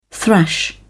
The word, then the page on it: thrash: British English